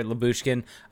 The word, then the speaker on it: Lyubushkin: did I say it right there? Lyubushkin? – Yes.